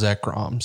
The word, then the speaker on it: Zekrom: I have always known it as Zekrom, so I do not have any controversial pronunciations to give the community this week for Lashback.